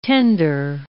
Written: tender

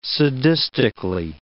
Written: sadistically

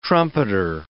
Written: trumpeter

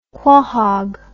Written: quohog

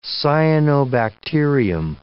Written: cyanobacterium

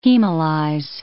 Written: hemolyze

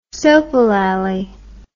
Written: soapolallie

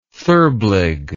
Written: therblig

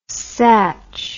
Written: satch

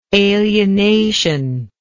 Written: alienative

